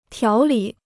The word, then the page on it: 条理: tiáo lǐ